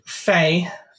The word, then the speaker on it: Fai: In French and Spanish, it is Fay, or at least I think that's how it would be pronounced, as in... – Oh, like, he's right. It's right here. Pronunciation, F-A-I. Fai.